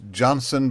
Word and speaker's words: Jansen: It's pronounced Johnson, but it's spelled Jensen because she's so Dutch.